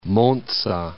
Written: Monza

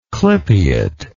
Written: clypeate

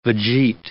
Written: vegete

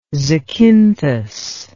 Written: zacynthus

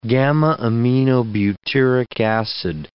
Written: gamma-aminobutyric acid

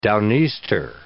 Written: down-easter

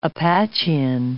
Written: Apachean

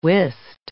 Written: whist

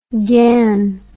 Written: ghan

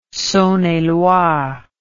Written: Saone et Loire

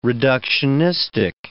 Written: reductionistic

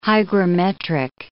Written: hygrometric

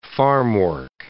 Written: farmwork

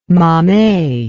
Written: mammee

